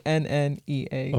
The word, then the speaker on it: Enneagram: The one that I'm talking about is called the Enneagram. It sounds weird. It's spelled Enneagram. If you want to look it up. – It's Enneagram.